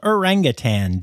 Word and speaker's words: Orangutan: And if you're listening the day this came out, well, that's the 19th, and that's World Orangutan Day. Make sure you say it right, and don't add that G, folks.